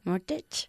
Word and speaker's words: mortgage: Mortgage. – Mortgage, sí, la T es silenciosa.